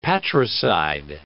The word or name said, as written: patricide